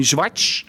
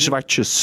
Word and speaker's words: Zwartjes: Nee, maar ik bedoel, jouw achternaam, achternaam dient zeg maar in de, in de, in de kleinvorm uitgesproken te worden. Of, ja. Het, het is niet eh, Tommy Zwarts, Tommy Schwartz, maar Tommy oh, Zwartjes. Zwartjes.